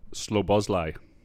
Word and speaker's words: Szoboszlai: Szoboszlai.